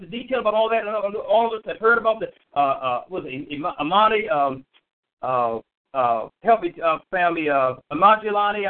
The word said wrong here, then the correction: Illuminati: I'm pronouncing it wrong. Illuminati. I think I got it.